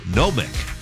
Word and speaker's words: gnomic: Gnomic. It's spelled G-N-O-M-I-C. Gnomic.